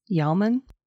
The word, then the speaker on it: Yeoman: 2019 BBC Scotland News article by Louise Yeoman. Is that how you say it? Y-E-O-M-A-N? – I don't think so.